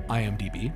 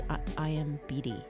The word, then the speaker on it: IMDb: It's from the ever-reliable source of IMDb. – Mm-hmm. You know, I always pronounce it wrong. I'm always IMBD, which is not what it is.